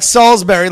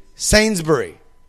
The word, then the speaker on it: Sainsbury's: Like Salisbury, like the steak, right? – No. – Is that not, wait, how do you say it? – Sainsbury. – Sainsbury. – Sainsbury. – It's like Sainsbury.